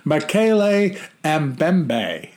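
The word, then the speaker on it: Mokele-mbembe: Yeah, then Matele Matumbo, whatever. – Makele Mbembe. – Oh, there you go. Now I got Makele Mbembe.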